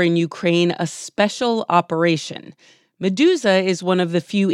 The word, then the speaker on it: Meduza: Meduza.